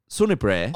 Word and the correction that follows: Sunnybank: Oh, Sunnybank.